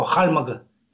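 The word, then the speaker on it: Хальмг: Ну, калмыки произносят его «хальмага».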